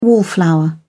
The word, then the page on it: wallflower: British English